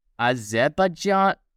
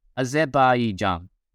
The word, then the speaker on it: Azerbaijan: So the first is Azerbaijan. I don't, holy crap, I butchered that so much. Azerbaijan. A bit Azerbaijan.